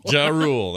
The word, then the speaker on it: Ja Rule: Ja Rule.